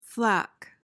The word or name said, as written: flak